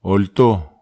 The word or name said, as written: ol to